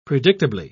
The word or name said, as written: predictably